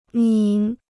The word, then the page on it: 拧: níng